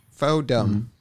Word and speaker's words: Fordham: Fordham. It's pronounced Fordham. Yeah. – Foden? – You don't pronounce the Ford in the ham. It's Fordham. Fodum.